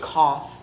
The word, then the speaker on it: kaf: The C, which is pronounced kaf in Hebrew, means like.